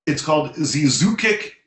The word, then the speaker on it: Zyzzyx: Yeah, and so, you know, it's got people in it that, you know, that you've heard of, but the thing is, it's called Zizoukic Road, but Zizoukic is spelled, and I think that's how you pronounce it, is spelled Z-Y-Z-Z-Y-X Road.